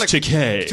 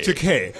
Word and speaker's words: Takei: We're like, it's pronounced Takei. – Takei. Mr. Takei.